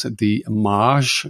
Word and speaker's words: DeMaagd: It's Kurt with a K, K-U-R-T, and then there's a space, D-E-M-A-A-G-D, although you pronounce it as the Maj, you suggested.